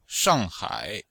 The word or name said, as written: shang4hai3